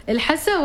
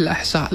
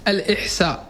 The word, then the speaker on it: الأحساء: كنت أقول قبل الله نبدأ اللقاء، أقول له هل يقولون الإحساء ولا الحسة ولا الأحساء؟ – نعم، النطق العامي الحسة. – الحسة. – نعم، النطق الصحيح الأحساء، لأن في كثير يقولون الإحساء. – أيوة. – وهذا غير صحيح.